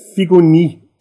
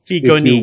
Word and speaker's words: Figoni: Is it pronounced Figoni? – Figoni. Figoni. If you want to pronounce it in French. But he was Italian, so Figoni.